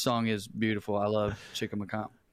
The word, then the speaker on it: Chicamacomico: Here is Chicamacomico.